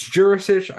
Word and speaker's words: Djurisic: It's also spelt two different ways, probably because us dumb Americans need to see the J to say it right. So on some sites, it's spelt D-J-U-R-I-S-C-I-C or S-I-C, sorry. Some accounts, it's just D-U-R-I-S-I-C. So it's Djurisic. I know that much.